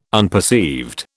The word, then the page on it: unperceived: British English